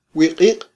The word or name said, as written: wiq'iq'